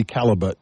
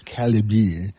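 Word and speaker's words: Callebaut: Barry Calabier. He's a Belgium guy. Mm-hmm. He's probably... In English, it would be Calibut, but apparently it's Calibier.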